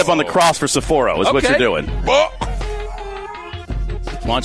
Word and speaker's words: Sapporo: Sapporo. – Accent on the first syllable. – Gotcha. Okay. That's it. Yeah. – I like Diener going Sapporo.